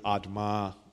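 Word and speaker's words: Adamah: Adma is um, how you say earth in Hebrew.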